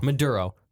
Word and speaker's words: Maduro: So basically, the current president, President Maduro, I think I'm saying that right. – Maduro.